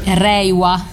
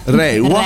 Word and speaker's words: Reiwa: Dicevamo appunto imperatori che determinano le ere, quindi epoca Showa con Hirohito, epoca Eisei con Akihito, epoca eh, Rawa, detto bene? – Reiwa. – Reiwa. Reiwa.